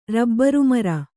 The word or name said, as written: ರಬ್ಬರುಮರ